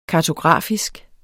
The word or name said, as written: kartografisk